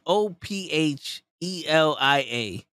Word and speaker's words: Ophelia: So his name is spelled O P H. E-L-I-A. – O-P-H. – E-L-I-A. – Of E-L-I-A.